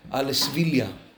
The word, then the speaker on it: Alesvilia: His last name really was Alesvilia. Why? The Ritba was from Seville in Spain. There's a city in Spain called Sevilla. Al Sevilla means what? From Seville. So that's the proper way to pronounce his last name.